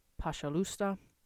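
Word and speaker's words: pashalusta (пожалуйста): And it is spelled P-O-Z-H-A-L-U-Y-S-T-A, pashalusta. Next, we have the phrase prestimenya, which means forgive me, and it is spelled P-R-O-S-T-I space M-E-N-Y-A, prestimenya.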